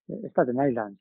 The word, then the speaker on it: Staten Island: Staten Island, ¿cómo se dice? – Staten Island, sí, sí.